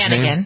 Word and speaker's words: Hannigan: Nicholas Brandon, Allison Hannigan, is that how you pronounce Hannigan. – Her name? Hannigan, okay.